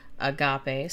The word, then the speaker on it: agape: So the Greek word agape, pronounced, of course, agape, spelled A-G-A-P-E, and variations of it are frequently found throughout the New Testament, but rarely in non-Christian Greek literature.